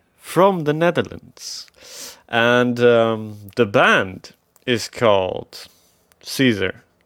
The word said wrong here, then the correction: Kieser: Caesar? Caesar? No, it's called Kieser, sorry. In the beginning, there were a lot of organizers and people confused about how you pronounce their band name, but it's Kieser.